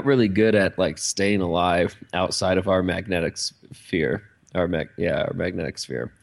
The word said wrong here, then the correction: magnetosphere: I think I'm still kind of saying that one syllable off, but I don't care. – Magnetosphere. – Yeah, magnetosphere. That's it.